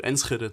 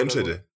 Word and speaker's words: Enschede: Oder Enschede. Ich weiß nicht, was sagt man im Deutschen? Enschede? Enschede habe ich ganz oft gehört, mit K okay. quasi, ja. ja. Weil wir das CH nicht aussprechen können, deswegen.